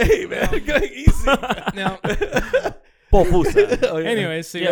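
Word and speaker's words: pupusa: I had a papusa today. Did you? Yeah, it's good from is that how you say it? No, popusa.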